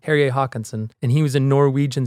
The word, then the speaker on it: Terje Håkonsen: Terje Håkonsen.